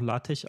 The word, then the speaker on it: LaTeX: Und ähm, das, deswegen spricht man es auch LaTeX aus übrigens, weil es ein Chi ist und kein X.